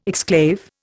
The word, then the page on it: exclave: Indian